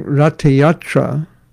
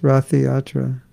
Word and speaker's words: Ratha Yatra: And so during Ratha Yatra, That's how it's pronounced. It's not pronounced Rathiyatra.